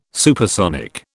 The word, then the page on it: supersonic: British English